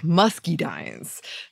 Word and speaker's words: muscadines: Also, most people pronounce the A as more of an IE sound, like musky dines.